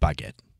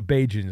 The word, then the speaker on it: Bagent: I've heard it's pronounced Bagent. I don't know, but we should just stick with Baguette. – Well, no, Bajan is the actual name, but I think Baguette's more fun.